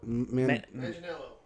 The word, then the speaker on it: Manganiello: I don't know anything about him, but like, I'm going to pronounce his name awfully wrong. Joe Mangianni. – Manganiello. – Yeah, that one.